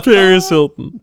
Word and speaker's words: Paris Hilton: Harris Pilton. Being Harris Pilton would be terrible. – Paris Hilton. – Did I say it wrong again? – Yeah, you did.